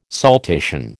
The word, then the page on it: saltation: American English